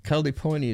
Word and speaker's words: Kaliponi: Kula in Maui was nicknamed New Kaliponi because California was how they, Kaliponi is how the Hawaiians pronounce California.